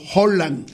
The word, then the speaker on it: Haaland: Se pronuncia fonéticamente como Holland. – Holland. – O con H o con J. Holland. Con una J suave y aspirada. La doble A del noruego original, Haaland, se pronuncia como una O alargada. Mientras que la D al final es muda y muy sutil.